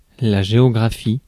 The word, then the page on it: géographie: France; /ʒe.ɔ.ɡʁa.fi/